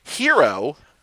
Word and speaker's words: gyro: Like falafel, hero. – Spanish opera. – Ooh, hero, but it's spelled gyro.